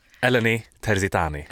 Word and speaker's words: Eleni Terzitani: Petri Dingatas, Eleni Terzitani! Snabbt, uttalar vi rätt? Eleni Terzitani. – Alltså helt perfekt.